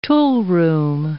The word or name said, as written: toolroom